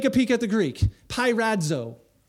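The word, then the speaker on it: Pirazzo (πειράζω): Yeah, how do you pronounce that? – Pirazzo.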